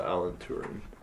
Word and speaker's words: Alan Turing: A-L-A-N, obviously, for Alan. And then, T-U-R-I-N-G. Yeah, Alan Turing.